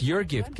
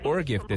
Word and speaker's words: Urgift: Eh, your gift, como tú lo decías, es Ah, es your gift, qué pena, no es or gift, es your gift. Perdón, la U se pronuncia como you.